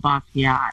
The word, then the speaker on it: Basquiat: Basquiat? What, you put it in a basket? Is that what you said? – You work at this museum and you don't even know how to pronounce it? Jean-Michel Basquiat.